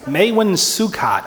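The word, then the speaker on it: Maewyn Succat: Maywin Sukkot, I guess, is about the best way you can go about pronouncing that if you speak Gaelic.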